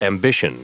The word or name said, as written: ambition